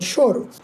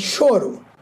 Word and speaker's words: choro: It's called Choro. – Yes. Can you tell everyone that it's pronounced that way and it's spelled C-H-O-R-O. So it's not correct. Choro. It's choro.